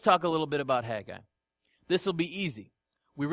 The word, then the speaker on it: Haggai: His name in Hebrew is pronounced Haggai, which means festival.